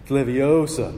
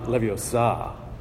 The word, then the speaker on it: Leviosa: It's like, for you Harry Potter fans, it's Leviosa, not Leviosa, right?